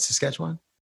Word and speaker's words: Saskatchewan: Saskatchewan? – Sure.